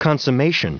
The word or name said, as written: consummation